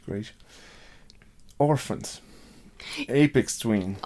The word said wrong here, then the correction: Aphex Twin: Yeah, Aphex Twin, yeah.